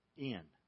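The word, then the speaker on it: In (ἐν): Here's how you pronounce the word in Greek. In. Now, it would be spelled E-N.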